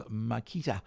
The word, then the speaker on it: Marcheta: That was Makita, not the drill. It was Makita, you could almost pronounce it, M-A-R-C-H-E-T-A.